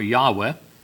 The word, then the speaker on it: Yahweh: In our King James, they capitalize the word that actually is the Hebrew word for Jehovah, Yahweh, the way it's pronounced in the Hebrew.